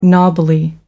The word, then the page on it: knobbly: American English